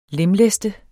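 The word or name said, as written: lemlæste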